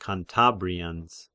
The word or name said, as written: Cantabrians